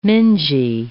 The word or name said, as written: mingy